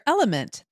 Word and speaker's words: LMNT: And when we come back, we're going to actually offer some Sarah, we're welcoming our sponsor, Element. That's spelled L-M-N-T,